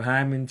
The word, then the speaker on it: hymen: situation is you know, annually he takes his daughter to have her hymen checked. Did I say that correct? – Yeah, you said it correctly.